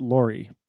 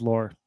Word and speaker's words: Lore: I think you spell it L-O-R-E. – Yeah. – Is it Lohr? – That is correct. Let me see. It is Lohr. You were right.